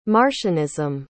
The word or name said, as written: marcionism